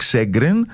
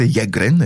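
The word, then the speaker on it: Sjögren: Σέγκρεν. Στα σουηδικά προφέρεται Γέγκρεν, έτσι για να είμαστε και πιο ακριβείς, ο οποίος το περιέγραψε για πρώτη φορά το 1933.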